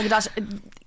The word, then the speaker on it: Gadot: Gal Gadot... – I know it sounds wrong, but I believe that is actually the correct pronunciation. It's to do with she's Israeli, and apparently that's Anyway, I know it sounds wrong.